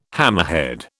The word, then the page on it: hammerhead: British English